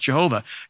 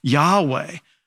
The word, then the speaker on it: Yahweh: Sometimes you'll hear it mispronounced Jehovah, Yahweh.